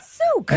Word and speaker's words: Suck: Because it's spelled S-U-C-K, but it is actually pronounced? – Souk. – Souk. – Michael Souk.